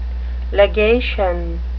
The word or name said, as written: legation